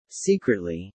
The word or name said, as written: secretly